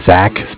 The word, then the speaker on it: Zach: My name is Zach, spelled Z-A-C-H. – Z-A-C-H. Last name, please. – Miller, M-I-L-L-E-R. – M-I-L-L-E-R.